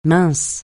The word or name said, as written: mince